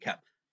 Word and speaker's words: Koepp: but it's funny because you really do like, could you imagine like I'm going to assume that because let's see, David Koepp, right? That's Yep. – how you pronounce it, Koepp.